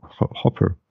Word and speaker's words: Hooper: Und der Typ ist Ian Hooper, Hopper, Hopper? Ian H-O-O-P-E-R. Wie wird das ausgesprochen? Hopper. Hopper. – Hopper. – Hopper. – Ja. Hopper.